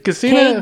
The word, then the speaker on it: Kusina: Kusina. K-U-S-I-N-A.